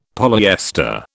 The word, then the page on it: polyester: British English